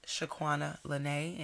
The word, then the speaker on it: Shaquanalanay: at Shaquana Lene. And it's spelled the way it sounds, S-H-A. Q-U-A-N-A-L-A-N-A-Y.